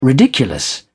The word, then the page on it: ridiculous: British English